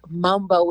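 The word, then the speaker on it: Mumbo: It's Mambo with a U, boo.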